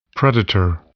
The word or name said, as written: predator